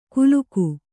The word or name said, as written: ಕುಲುಕು